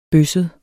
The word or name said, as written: bøsset